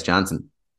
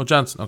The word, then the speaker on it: Jansen: It said, for TV and commercial purposes, the J is pronounced. So yeah, it's Jansen. – Oh, Jansen. Okay, cool.